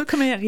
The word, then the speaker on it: Khmer: Ja, Khmer zeggen ze zelf, Cambodjaans. Ja, ja, ja, klopt, en, ja. En dan ga je als niet Cambodja of Khmer, zeg ik dat goed? – Khmer, ja.